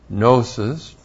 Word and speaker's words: gnosis: Now, we have in the Greek gnosis, G-N, long O-S-I-S.